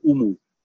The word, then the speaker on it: umu: You know, it's like I said, we call it an emu, they call it an umu. – Yeah. – U-M-U, whereas we pronounce it I-M-U, yeah? Emu.